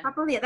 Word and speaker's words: Papalia: Allie, I always don't know if I'm saying your last name right. Is it, I'm going to butcher it, Papalia? – That's good. It's- No? – You said it right the first time.